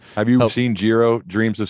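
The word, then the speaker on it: Jiro: Is definitely in one of the last stories I'll share, and want to obviously ask you a few questions, but, there was Euro Uno. Did I say that right? Make sure I got that. Did I say that? – Giro. – Okay.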